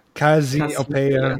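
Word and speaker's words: Cazzi Opeia: Don't pronounce it Italian. – Cassiopeia, yeah, sorry. Cazzi is something else in Italian.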